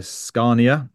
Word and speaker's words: Scania: And then I'm blessed also to be in Stockholm today at the Sustainable Transport Forum hosted by Scania.